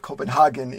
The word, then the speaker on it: Copenhagen: We would call it Copenhagen, and maybe they call it Copenhagen in Europe.